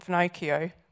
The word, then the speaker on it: Finocchio: This book is called Killer Church by Nathan Finocchio. Rhymes with Pinocchio, very handy.